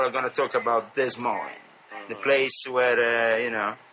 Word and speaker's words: Des Moines: I never know how to pronounce it myself. Do they say Des Moines in America? Is that how they pronounce it in America? – Des Moines. It's a great place, Des Moines.